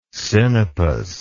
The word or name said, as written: sinopis